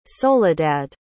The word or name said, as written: soledad